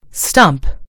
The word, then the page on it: stump: British English